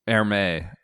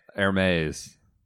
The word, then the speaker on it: Hermes: Hermes, Hermes. I don't know if I'm pronouncing that right. I think it's right.